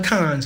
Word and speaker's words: Cannes: It's spelled Cannes, so it's Cannes. C-A-N-N-E-S. Cannes in France.